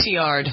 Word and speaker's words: Cotillard: All right, so who's Marion, is it Cotillard? How do you pronounce her name? – Cotillard.